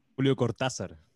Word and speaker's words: Julio Cortázar: Julio Cortázar.